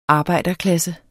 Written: arbejderklasse

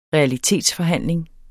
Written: REALITETSFORHANDLING

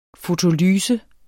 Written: fotolyse

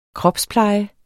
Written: kropspleje

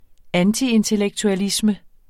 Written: antiintellektualisme

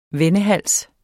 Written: vendehals

